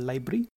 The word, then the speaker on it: LBRY: So the first such project that we just want to quickly talk about today is called Libry, which is spelled as LBRY.